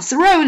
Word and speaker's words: Thoreau: Thoreau 呢，这个音的发音啊稍微有点难啊， Thoreau。